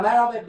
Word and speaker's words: merhaba: Yeah, merhaba means hi in Turkish language.